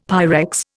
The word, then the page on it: Pyrex: Indian